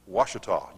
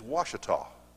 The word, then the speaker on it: Ouachita: Ouachita. Are you from Arkansas? There you go, see? It is Ouachita.